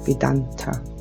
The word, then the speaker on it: Vedanta: Vedanta, richtig ausgesprochen.